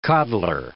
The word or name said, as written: coddler